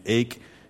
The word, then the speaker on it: Ake: If we have a name that's a little bit unusual, my last name, Ake, is one that I've heard mispronounced since I entered kindergarten. Since people started looking at it on lists, it's been Akey, Ack, Aki. You know, they like to put the accent on the second syllable that's not there. And it's an unusual name. I'll give you that. It's a name that causes people consternation. They can't believe that it's just as simple as Lake without the L.